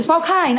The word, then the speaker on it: Spokane: Yeah, you go Spokane.